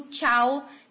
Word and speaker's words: ciao: que se escreve C-I-A-O, tá? Ele, então, transformaria-se, né, em tchau, que o CH, esse som que no italiano só o C tem o tch, o som de tch, no espanhol é o CH, por isso fica tchau, C-H-A-O.